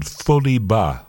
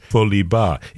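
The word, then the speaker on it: Foli Bah: 11 years on from that first album, they've now returned with a new album called Foli Ba. I will spell that for you as well. That's F-O-L-I is the first word, and then B-A-H, Foli Ba.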